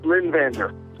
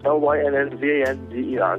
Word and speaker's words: Lynn Vander: Now I don't know how to pronounce the name of your company. Could you help me with that? – That's Lynn Vander. L Y N N V A N D E R.